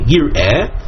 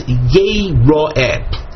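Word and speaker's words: yeh-ro'eh (יראה): So the word is spelled yud, resh, aleph, hey, yir'eh. However, we read that as yeh-ro'eh.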